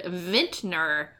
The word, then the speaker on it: vintner: I think I had guessed vinter, which is that's me mispronouncing the word vintner, which is an old-timey word for a wine merchant.